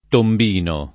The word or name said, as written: tombino